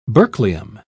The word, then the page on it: berkelium: British English